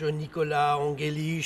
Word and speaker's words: Nicolas Angelich: Martha Argerich, Nicolas Angelich, je ne sais pas si je prononce bien.